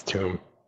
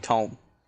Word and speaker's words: Tomb: It's Tomb. – I like saying Tome. – A tome is something you read.